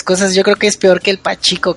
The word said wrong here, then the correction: pachinko: ¿Cómo se pronuncia esa pinche máquina? – Sí, la máquina. Sacaron una máquina de pachinko de Silent Hill 2, de hecho.